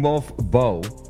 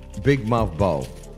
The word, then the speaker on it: Mouf'Bo: Is that Moth Bow? I was trying to get the pronunciation of this artist, but I think it's a uh, Big Moth Bow. Big Mouth Bow. Uh, M O uh, mouth mouth, but it's spelled mouth. Yeah, big mouth bow.